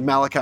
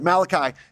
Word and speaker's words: Malachi: actually Malachi is how you pronounce it, Malachi, in Malachi 3, he's explaining why the people were not experiencing God's blessing.